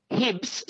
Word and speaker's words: Hibbs: And did you say his name? His name's Hibbs? Hibbs? – Yes. I'll just spell the middle name. Kenny, K-E-N-N-E-Y. – Yeah. – Hibbs, surname, H for Harry, I-B-B-S.